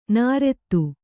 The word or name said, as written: ನೈಋತ